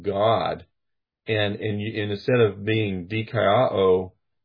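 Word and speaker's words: dikaiō (δικαιόω): It's kind of hard to say, but if you write it in English, it's obviously a Greek word, but in English, it's D-I-K-A-I, and then a short O and a long O, dikaiō.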